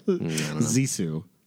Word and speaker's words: Zissou: No, Life Aquatic with Steve Zissou. Whatever it's called. – It's two syllables. Zissou.